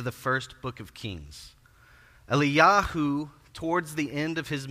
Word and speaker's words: Eliyahu: And so today in the interest of alleviating this confusion, when I talk about Elijah, I'm going to use his Hebrew pronunciation which is Eliyahu.